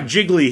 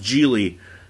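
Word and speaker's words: Gigli: Which, in the first 5 minutes, they make fun of the fact that his name is Geely. He's like, oh, yeah, I got Jiggly here. He's like, oh, it's pronounced Geely.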